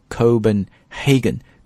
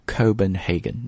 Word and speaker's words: Copenhagen: Was on loan，注意这个介词的搭配 to a Copenhagen bar. Copenhagen is the capital city of Denmark，是丹麦的首都哥本哈根 Copenhagen。注意在读这个词的时候千万别按中文的发音读哥本哈根啊， Copenhagen Copenhagen 啊，第一个 O 发的是 O 音，不是哥啊，不是科，是 Copenhagen 啊，在这里注意一下发音，bar 就表示酒吧的意思，which had a collection of vodkas on display。